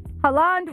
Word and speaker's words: Haaland: The other one that I have for you guys, sorry, and I got to compose myself, is Haaland, right? That's how you say his name? – Yeah. – Haaland. Okay. Haaland was asked about the World Cup, and he had something very interesting to say.